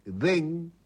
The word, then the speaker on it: Them: Estas bandas son DEM, que se escribe THEM, Bichos de Candy, Alma Pura y Virgen, Virgen con M.